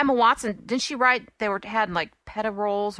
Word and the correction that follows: profiterole: Profiterole. Oh, God. I said it wrong.